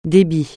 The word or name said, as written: débit